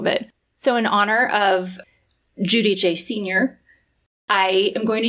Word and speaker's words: Giudice: So it was, she said her daughters and her and Joe had never really been bothered by the American pronunciation, but they did correct people and try to pronounce it Giudice while Joe's father was still alive.